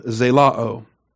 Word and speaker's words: zelao: And the verb, as I said, is really a noun that was turned into a verb, zelao. Z-E-L-O-O. And it's one of these Omicron Omega endings on your a-o verbs. Short O, long O, zelao.